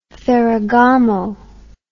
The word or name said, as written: ferragamo